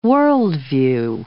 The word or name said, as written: worldview